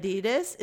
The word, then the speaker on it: Adidas: Adidas is actually pronounced Adida.